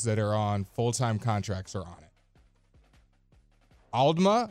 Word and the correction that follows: Aldama: Santi Aldama.